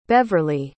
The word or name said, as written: Beverly